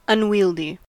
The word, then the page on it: unwieldy: American English